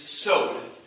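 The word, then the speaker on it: sod (סוד): And then the last thing is sod. Rhymes with load.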